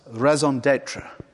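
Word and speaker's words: raison d'être: This is the, let me try this, raison d'etre. Is that right? Leonie corrected me before. She said it rhymes with maison.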